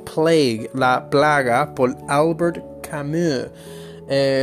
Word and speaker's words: Camus: Obviamente el Camus no se pronuncia Camus, se pronuncia Camus. Porque él es francés y los franceses son así.